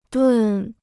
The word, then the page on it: 炖: dùn